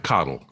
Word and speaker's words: Cottle: Uh, Frank Cottle.